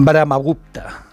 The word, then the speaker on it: Brahmagupta: Brahmagupta lo he dicho bien, mira. Sí, sí, bastante bien, buena pronunciación india.